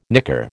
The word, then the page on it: nicker: American English